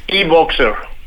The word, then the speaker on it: e-Boxer: ¿Qué tanto los compradores tienen que hacer y esperar por tener su e-Bowser? E-Bowser, ¿no? Así se pronuncia. – E-Boxer. – Boxer. E-Boxer.